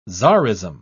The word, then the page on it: tzarism: British English